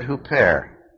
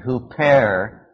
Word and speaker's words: huper: The word "for" is the Greek word "huper." Spell it H-U-P-E-R.